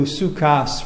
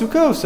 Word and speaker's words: Sukkos: In other words, why was it changed to sukkas from sukkos? For this drasha. And there's nothing achinam because it's pronounced sukkos. That's just the way it's pronounced.